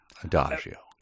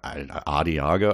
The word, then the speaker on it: Adagio: And are you going to leave that pronunciation of Adagio out there for everyone? I'm looking out for you. Adagio. – Adagio, what did I say? – Adiago, I think you pronounce Asiago cheese.